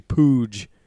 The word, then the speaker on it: Puig: And it's actually Pooge. But it is spelled Puig.